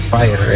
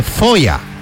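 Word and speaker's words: Feuer: Bueno, es que hay varias, pero Fire, esa es la que puedo pronunciar. – No. Repeat after me, bye. Repeat after me. Se dice Foya.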